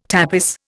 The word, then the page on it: tapis: Indian